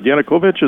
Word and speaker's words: Yanukovych: But talk about this, the election of the president, and I hope I have it right. Yanukovych, is that correct? The former president. – That's very good. You got it perfect. President. Exactly how you pronounce it.